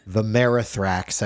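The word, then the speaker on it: Vermithrax: Vimerithrax, I believe, is how you pronounce it.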